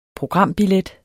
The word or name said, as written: programbillet